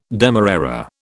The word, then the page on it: demerara: British English